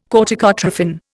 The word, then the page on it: corticotrophin: Indian